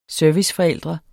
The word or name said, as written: serviceforældre